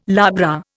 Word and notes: labra: Indian

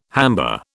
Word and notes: hamba: British English

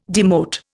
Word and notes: demote: Indian

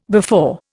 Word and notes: before: Indian

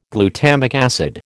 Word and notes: glutamic acid: American English

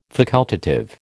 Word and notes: facultative: American English

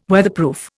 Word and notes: Weatherproof: Indian

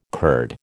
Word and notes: Kurd: American English

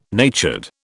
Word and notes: natured: British English

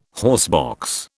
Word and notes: horsebox: British English

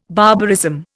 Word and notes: barbarism: Indian

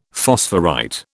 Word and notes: phosphorite: British English

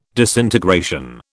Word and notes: disintegration: British English

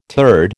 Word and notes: turd: American English

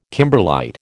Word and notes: kimberlite: American English

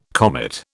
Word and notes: comet: British English

